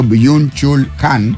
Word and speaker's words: Byung-Chul Han: Byung-Chul Han.